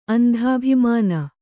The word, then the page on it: ಅಂಧಾಭಿಮಾನ: andhābhimāna